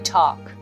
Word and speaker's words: talk: We need to talk はいこのトークの発音難しいかなと思いますね。 T-A-L-K このここの部分に L が入っているのはとても発音しにくい。 Talk Talk うん。 talk っていうよりも talk, talk. We need to talk.